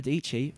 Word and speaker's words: Dietschy: Sarah Dietschy rhymes with Peachy.